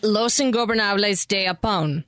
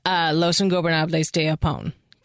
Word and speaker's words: Los Ingobernables de Japón: You can, Los Ingobernables de Japón. – Thank you. – Wait, you what, can what, do what, it. – What? – Yeah, Los Ingobernables de Japón.